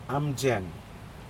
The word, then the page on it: aMjeng: Aneityum